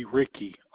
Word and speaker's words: Ricky: First name is going to be Ricky, R-I-C-K-Y. Last name, Rogers, R-O-G-E-R-S.